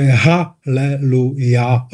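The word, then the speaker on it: ha-le-lu-ja (הללויה): Třeba nevím, příkladem v latině se říká aleluja, jako sláva pánu nebo chvalte pána, aleluja, ale jako v hebrejštině to je ha-le-lu-ja. Tam je na konci, no to se nevyslovuje, ale tam to na konci je ha-ja-ha-le-lu-ja-h, chvalte Jahveho.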